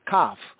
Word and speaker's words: Kaf: At the 81st verse, going eight verses down, we have Kaf, Kaf, not Saf. The C was not pronounced as an S sound. That's only in late-today confusion English, as Kaf.